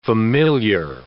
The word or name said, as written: familiar